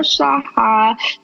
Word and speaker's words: Shah: And today we are joined here with Ankur Shaha. Please let me know if I'm saying your name right. So. – Yeah, yeah. You, uh, Shah, you said it. You said it pretty accurately.